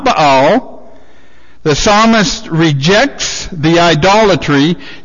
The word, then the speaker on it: Baal: Now, that's B-A-A-L. And the way you pronounce it is not Baal or Baal. It's Baal. That's the proper pronunciation of it.